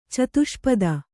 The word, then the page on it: ಚತುಷ್ಪಥ: catuṣpatha